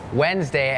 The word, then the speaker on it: Wednesday: Wednesday has that D W E D. It's not wed Wednesday D is silent